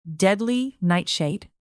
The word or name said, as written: deadly nightshade